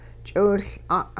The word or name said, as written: ch'iwiL'a'